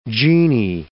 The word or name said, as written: genie